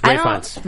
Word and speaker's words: Ralph Fiennes: How do you say it? Ralph Fiennes? – Ralph Fiennes. – Yes. I don't know. – Yeah. Ralph Fiennes. Ray Fiennes. – Ray Fiennes.